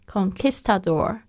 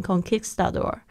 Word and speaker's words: conquistador: 只是那个 conquistador, 我一直很想要念成西语的那个发音 conquistador, 好像那个英语的发音，那个应该是在 Q 上面 conquistador.